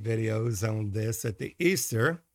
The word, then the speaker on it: Ishtar: Now, Easter comes from Ishtar, I-S-H-T-A-R, which is pronounced Easter, which is the daughter of Tammuz, a queen of heaven.